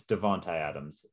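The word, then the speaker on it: Davante Adams: Also, one thing I did see today, is it Devontae Adams? It's Devontae Adams. That's how you say it.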